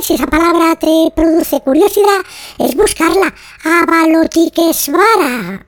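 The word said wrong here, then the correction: Avalokiteshvara: Ah, no, lo he dicho mal. Avaloquitesvara.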